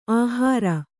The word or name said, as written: ಅಹೇರು